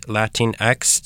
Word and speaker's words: Latinx: La Liga is an online platform and print zine that showcases the many ways Latinx, did I say that right? – Yes.